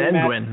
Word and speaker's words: Zenguin: The Zenguin, am I saying that properly? – Zenguin, that's right. – The Zenguin, excuse me. Like Penguin, Zenguin, duh.